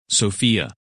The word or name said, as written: Sophia